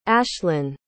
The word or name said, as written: ashlen